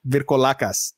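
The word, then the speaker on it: Vrykolakas: It's called the Virkolas. Virkolakas. There you go.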